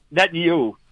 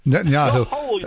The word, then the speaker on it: Netanyahu: The Pope met with his cardinals to discuss a proposal from Benjamin Netanyahu, the leader of Israel. I may have butchered that name. I apologize, Benjamin. – Netanyahu.